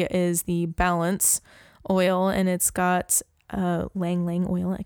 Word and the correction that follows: Ylang Ylang: It's Ylang Ylang. – Ylang Ylang. I can never say that right.